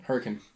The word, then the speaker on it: Hurricane: This place called Hurricane, which is spelt Hurricane. They pronounce hurricane. it Hurricane. Hurricane.